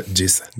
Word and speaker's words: GZA: Ya aquí es ya cuando The Genius se hizo The Giza, que se escribe G-Z-A. Simón. Pero se pronuncia Giza. Giza. Prince Rakim es The Risa, que es R-Z-A.